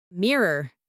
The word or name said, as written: mirror